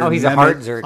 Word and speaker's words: Mehmet: Mehmet Oz, yeah.